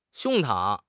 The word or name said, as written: 胸膛